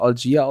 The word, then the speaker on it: Allgeier: Ich fand es jetzt kein gutes Footballspiel und der Allgeier wird ja auch eigentlich Algier ausgesprochen.